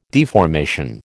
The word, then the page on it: deformation: American English